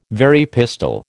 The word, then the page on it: Very pistol: American English